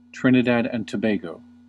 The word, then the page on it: Trinidad and Tobago: American English